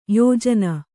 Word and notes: ಯಾಜನ: yājana

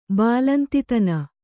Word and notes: ಬಾಲಂತಿತನ: Kannada

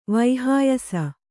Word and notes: ವೈಹಾಯಸ: Kannada

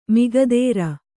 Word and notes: ಮಿಗದೇರ: migadēra

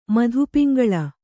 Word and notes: ಮಧುಪಿಂಗಳ: Kannada

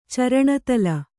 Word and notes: ಚರಣತಳ: caraṇa taḷa

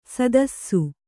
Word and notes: ಸದಸ್ಸು: Kannada

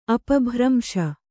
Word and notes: ಅಪಭ್ರಂಶ: apabhramśa

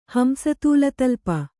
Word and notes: ಹಂಸತೂಲತಲ್ಪ: hamsa tūla ta talpa; Kannada

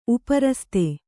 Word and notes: ಉಪರಸ್ತೆ: upa raste

